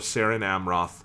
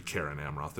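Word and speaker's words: Cerin Amroth: And taking Frodo's hand in his, he left the hill of Saren Amroth and came there never again as living man. That should be Karen Amroth. There's no soft C's in fucking Tolkien. In Sindarin?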